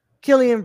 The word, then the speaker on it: Cillian: Killian Brad. It's K. It says here in the phonetic pronunciation K I L L E E A N.